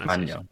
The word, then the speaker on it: Maignan: Mike Maignan, I think it's there you go, Mike Maignan.